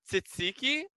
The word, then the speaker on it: tzatziki: First of all, bruschetta, feta, tzatziki. I don't even know how you got to the pronunciation you got to. – Tahiki. – There's not even an A. – Tahiki. – Tzatziki.